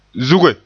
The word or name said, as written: zuge